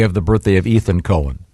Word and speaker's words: Cohen: Cohen. Yeah, you. – This that's confusing because not when it's spelled C-O-H-E-N. – Definitely Cohen.